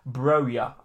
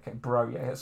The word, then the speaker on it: Broja: It's pronounced Broja. Okay, Broja.